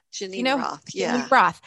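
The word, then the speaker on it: Geneen Roth: And I found the author, I believe her first name is pronounced Janine Roth. – Janine Roth.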